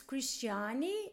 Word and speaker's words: Christiane: It's Christiane. So it's Christian with the E in the end.